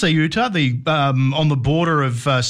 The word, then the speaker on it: Ceuta: Well, there were people who are migrants who were trying to get to Ceuta, I believe is the pronunciation, this autonomous city of Spain, and it's on the North African coast, so it's bordered by Morocco.